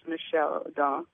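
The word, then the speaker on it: Michel Odent: And O'Donnell is spelled Odent, O-D-E-N-T, and his name is Michael, but he's French, so it's Michelle O'Donnell.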